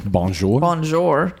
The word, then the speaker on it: Bonjour: So, anyway, so beyond that, you've got Gaston's Tavern is in the village, and Bijon, I said that wrong. – Bonjour. – Good Lord, I can't even say it. Bonjour Village Gifts.